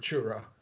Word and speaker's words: churro: I don't pay $12 for a churro. – Uptight churro flavored. – Let's just say it together. Churro. – Churro. – Roll your R's. Churro.